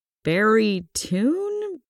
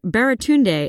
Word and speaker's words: Baratunde: For the record, Baratunde is pronounced Ba-ra-tunde.